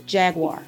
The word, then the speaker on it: Jaguar: I'm going to spell it phonetically for you. J-A-G-W-I-R-E. W-A-H-R. Jaguar. Say it with me.